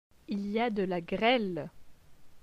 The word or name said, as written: Il y a de la grele